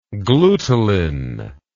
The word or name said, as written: glutelin